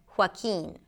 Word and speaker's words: Joaquin: Hua, hua, close your lips and then open it up for a W sound, hua, and then keen, a K sound, a high E, and an N, Joaquin.